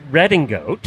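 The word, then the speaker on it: Redingote: We went back and gave as much free publicity as we could, and it's Redding Goat.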